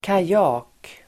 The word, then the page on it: kajak: [kaj'a:k]